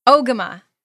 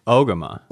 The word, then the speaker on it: Ogema: Ogema. Did you hear that? – Ogema. – Ogema. Okay. Just like I thought.